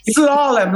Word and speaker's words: slalom: Slalom, like the skis.